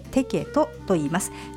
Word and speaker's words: TEKET: チケットは電子チケットサービステケット、これはアルファベットで TEKET と書いてテケットと言います。